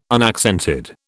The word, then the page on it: unaccented: British English